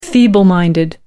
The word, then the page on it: feeble minded: American English